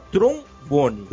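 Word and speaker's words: Tron Bonne: Aproveitando, para quem não conhece, eu vou sugerir mais um jogo que é relacionado ao Mega Man Legends, porém pouca gente conhece se chama trombone, da forma que se escreve, só que é T-R-O-N, Bone, B-O-N.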